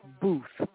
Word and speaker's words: Boothe: But those of you who are listening, just go to Amazon and put in the author's name, D'Amico Booth. Booth is spelled B. I'll spell it. B-O-O-T-H-E, like boot, and then H-E at the end. And D'Amico, yes, I'll spell it, D-E-M-I-C-O, D'Amico Booth.